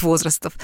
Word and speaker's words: возрастов: Да, и я, кстати, хочу поделиться своим открытием, которое я сама сделала, по-моему, 3 или 4 года назад, я просто узнала, что, оказывается, правильно говорить возрастов.